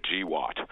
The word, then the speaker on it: GWOT: GWOT, and they actually pronounce it GWOT, an unfortunate acronym.